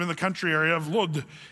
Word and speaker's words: Lod: Now, I just want to say, this is kind of fun here, because this place, Lydda, it's also in modern day, it's called Lod, L-O-D. That's how they say it in Israel, Lod.